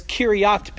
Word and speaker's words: Kiriath: So I'm pronouncing this as Kiriath because that's really closer to how it is in Hebrew. Hebrew really doesn't have a J sound.